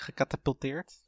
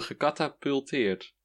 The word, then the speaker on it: gecatapulteerd: Is het niet gecatapulteerd? – Zei ik het? ik zeg gekatapuleerd, zeker. Oh, gecatapulteerd. Katapult. Katapulteren.